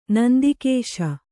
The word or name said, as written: ನಂದಿಕೇಶ